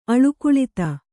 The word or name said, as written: ಅಳುಕುಳಿತ